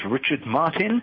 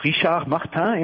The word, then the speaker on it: Richard Martin: My name is Richard Martin, pronounced Richard Martin in French, if you happen to hear me talked about in Paris, France.